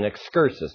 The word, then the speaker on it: excursus: It's here, then, that I said that Paul stops his point and he goes on to an excursus. Now, let's see if we remember. The word excursus, if you want to know how to spell it, it's E-X-C-U-R-S-U-S. It doesn't sound like how you would spell it.